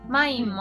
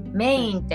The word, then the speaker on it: main: スタの方なんですけど、はい、マインドとかなひなということで、すべてまあ、はい、ローマ字打ちしていただいたらちょうどそれになるって感じですよね。 – そうですね、すいません、なんかちょっと難しいんですけど、マインもフランス語読みなので、 MAIN ですね、メインってこう、はい、読めるんですけど、マインと読みます。